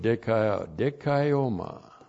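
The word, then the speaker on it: dikaioma (δικαίωμα): So here we have the new word in this word group of dikaioma. D-I-K-A-I long O-M-A. Dikaioma.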